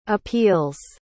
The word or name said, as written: appeals